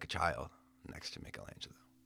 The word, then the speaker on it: Michelangelo: Michelangelo.